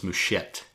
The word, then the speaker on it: Mouchette: Next week we'll be talking about 1967's Mouchette from Robert Bresson. I always pronounce the T, even though it's French.